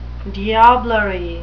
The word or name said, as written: diablerie